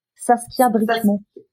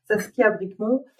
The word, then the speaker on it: Saskia Bricmont: Saskia Bricmont. – voilà, Saskia Bricmont.